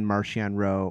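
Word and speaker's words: Marchion Ro: So when you listen to the book, you find out exactly how something's supposed to be pronounced, unless it's Markian, Martian Rowe, or Nihil, Nile.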